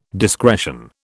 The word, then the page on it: discretion: British English